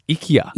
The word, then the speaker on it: IKEA: He calls it IKEA. – Yeah, apparently that's the actual way to pronounce it. – So my whole life we've been calling it IKEA. It's IKEA. – Yeah, IKEA.